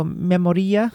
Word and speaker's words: Memoria: You're supposed to say it like it's Spanish. So Memoria.